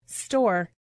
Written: store

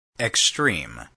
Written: extreme

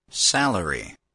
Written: salary

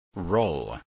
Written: roll

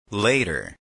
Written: later

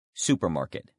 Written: supermarket

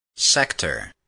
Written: sector

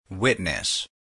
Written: witness

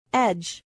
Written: edge

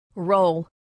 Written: role

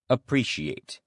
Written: appreciate

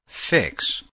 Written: fix

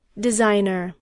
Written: designer

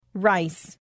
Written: rice

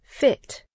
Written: fit

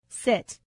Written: sit

